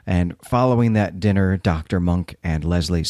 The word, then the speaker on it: Munck: And I do want to note that Dr. Monk's name is spelled M U N C K, though it is a homonym as well for monk, like a monk.